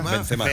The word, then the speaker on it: Benzema: Benzema.